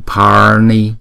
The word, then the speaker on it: Pani: Pani, Pani, không? Khi mà chúng ta đọc chữ A này có dấu gạch ngang thì chúng ta phải kéo dài hai nhịp. Chữ N thì chúng ta sẽ cong lưỡi lại và chúng ta búng ra N, N. Cho nên chữ này là chúng ta đọc là Pani, Pani, không?